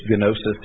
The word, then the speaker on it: gnosis: but it comes from the Greek word gnosis, or with the G that is silent when we say it in English, gnosis.